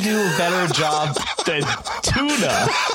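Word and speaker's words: Túna: Well, I think in, you know, it's pronounced Tuna. So, but it's still. I think that's how that, you know, if you're pronouncing it based on the actual language, that's what it would be.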